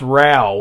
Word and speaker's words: Rauch: It's pronounced Rao, which is weird. – Okay. – Yeah, it's spelled R-A-U-C-H.